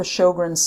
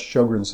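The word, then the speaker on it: Sjögren's: Well, okay, so first of all, what's Sjogren's syndrome? It's got a weird spelling, Sjogrens. – Yes. – That's from the Swedish. The J is silent.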